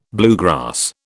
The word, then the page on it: bluegrass: British English